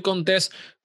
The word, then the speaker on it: Contés: Mi nombre es Miguel Contés, con acento en la E, y nos vemos en la próxima.